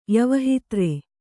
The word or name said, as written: ಯವಹಿತ್ರೆ